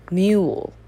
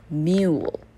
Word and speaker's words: mule: 最后一个第八 mule, mule. 所以你听到是 u. 所以它是 U space E, long vowel U 的发音哦。好，所以我们圈左边的。